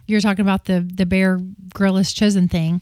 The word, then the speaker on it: Grylls: Grylls. Just like what you would do outside. – Is that how you say it? – Bear Grylls. – With a Y.